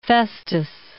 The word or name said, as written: Festus